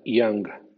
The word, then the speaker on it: young: Sin embargo, C-O-U-P suena Q, no suena la P, como en francés. Y-O-U-N-G suena Yang, en cuanto procedente del sajón antiguo, Dinamarca o nordeste de Alemania y los conjuntos AUGHT o OUGHT se tratarán más adelante.